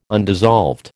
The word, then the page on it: undissolved: American English